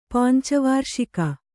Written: ಪಂಚವಾರ್ಷಿಕ

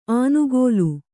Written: ಆನುಗೋಲು

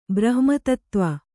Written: ಬ್ರಹ್ಮತತ್ತ್ವ

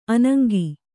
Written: ಅನಂಗಿ

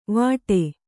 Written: ವಾಟೆ

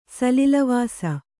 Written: ಸಲಿಲವಾಸ

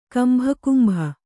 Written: ಕಂಭಕುಂಭ

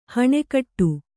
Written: ಹಣೆಕಟ್ಟು

